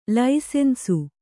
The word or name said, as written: ಲೈಸನ್ಸು